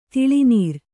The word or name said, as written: ತಿಳಿನೀರ್